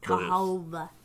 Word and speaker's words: Cahawba: Cahaba. – But it still says, it's still pronounced Cahaba. So the city is spelled C-A-H-A-W-B-A. So they throw a W in there.